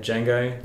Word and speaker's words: Django: And then I think 3 year age gap, we had Django. – Yeah. – Picked that name, Django Fett from Star Wars. So J-A-N-G-O. Yeah. Not Django with a D in front. – The D is silent.